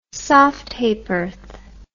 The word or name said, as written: soft ha'porth